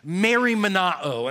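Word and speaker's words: merimnao (μεριμνάω): It's pronounced merimnao, and that means nothing at all. I'll just pronounce that way.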